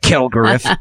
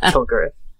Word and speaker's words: Kilgariff: Kilgariff. – Yeah, Kilgariff.